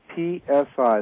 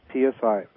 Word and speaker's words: PSI: PSI, that's P as in Paul, S-I, applications. That's PSI. PSI applications is how it's pronounced.